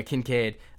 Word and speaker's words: Kincaid: Yeah, Kincaid.